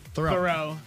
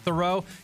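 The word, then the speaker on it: Theroux: I never know how to say his name. Justin Thurix? – Thoreau. – Thoreau, because the X throws me off.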